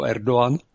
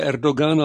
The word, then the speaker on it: Erdoğan: nebo Erdoğan. No. Jo, tam je Pjakino vyslovuje Erdogan, ale oni normálně ho říkají Erdoğan, asi to je turecky.